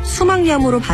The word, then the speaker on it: 수막염: 수막의 염증도 수막염이 아니라 수막염으로 발음해야 맞죠.